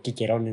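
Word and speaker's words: Cicero: No sé si sabes que Cicerón o Quiquerón en su pronunciación original logró a pura política, a puro verbo, detener al segundo César.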